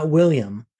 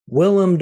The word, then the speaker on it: Willem: Platoon stars Charlie Sheen, Tom Berenger, I guess that's how you say his name, and William. I watched a interview on a talk show, and a lot of people would call him William Dafoe, but he was like, no, it's Willem Dafoe.